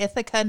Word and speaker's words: Ithaca: To Ithaca, New York.